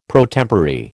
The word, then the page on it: pro tempore: American English